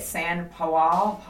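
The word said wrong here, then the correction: São Paulo: where were you guys? The balls to the wall video that you sent me. Where was that? – Sao Paulo, in Brazil.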